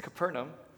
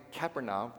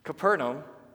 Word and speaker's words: Capernaum: It's Capernaum. I've been pronouncing it Capernaum this whole time. Noob. Capernaum.